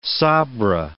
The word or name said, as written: sabra